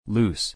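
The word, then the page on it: loose: /lú:s/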